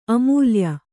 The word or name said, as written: ಅಮೌಲ್ಯ